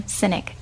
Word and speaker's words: Sinek: And his name is spelled S-I-M-O-N, last name Sinek, S-I-N-E-K.